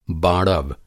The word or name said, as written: बाड़व